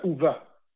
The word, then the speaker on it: Ove: How do you pronounce the name O-V-E? – Uva, like the middle part of Hoover. If you say Hoover real slow, you'll get it.